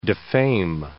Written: defame

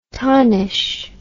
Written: tonish

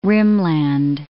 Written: rimland

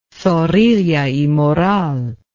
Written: Zorrilla y Moral

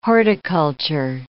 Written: horticulture

